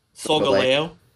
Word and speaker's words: Solgaleo: so how do you pronounce it? – Solgaleo.